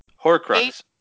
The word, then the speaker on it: Horcrux: I'd say it's almost like a Horrocrux. Am I saying that right? – What? – Horcrux. – Horcrux. – Oh. – Okay. – From Harry Potter.